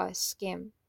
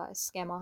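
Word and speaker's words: Schema: اول از همه باید بدونیم که پشت این پیشگویی ها یک چیزی وجود داره به نام طرحواره یا اسکیم یا به تلفظ آلمانی شما یا اسکما هم بهش میگن.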